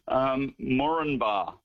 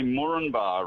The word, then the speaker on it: Moranbah: Yeah, well, they say Moranbah, right, the Queenslanders. However, I would have thought that you would have pronounced all of the vowels in that. So Moranbah. Okay, so, but they seem to like to drop their A's in many of their words, although, however, there are some town names where they like to add an A or add many A's.